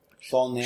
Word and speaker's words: Saulnier: How do I say this guy's name? Jeremy Saulnier. – Saulnier. – Sure, you win. – Yeah. Yes, I will take your pronunciation.